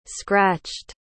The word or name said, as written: scratched